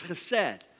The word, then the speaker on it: chesed: That word steadfast love in the Hebrew is chesed. Sometimes spelled H-E-S-E-D. Some people spell it that way. Some people spell it C-H-E-S-E-D. But it's chesed.